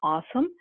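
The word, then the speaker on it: OSAM: And so this is the open source access manager pronounced as awesome.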